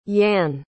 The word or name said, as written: yan